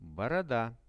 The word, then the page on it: boroda: Russian